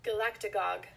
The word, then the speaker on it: galactagogue: Galactagogue. – Galactagogue. Okay, the trick is to slow it down. – Galactagogue. – Galactagogue. Galactagogue. Give that short A its rightful place in the word.